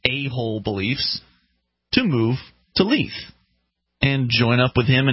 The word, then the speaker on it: Leith: Leith. Leith. I like Leith. Leith. – How's it spelled? – L-E-I-T-H.